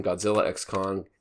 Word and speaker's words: Godzilla x Kong: Like, the X in Spy x Family, the X in Godzilla x Kong is not pronounced.